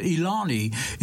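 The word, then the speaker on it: Eliane: Elaine, it looks like Elaine. I think she pronounces it Ilani. Ilani Elias, her name is.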